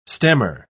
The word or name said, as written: stemmer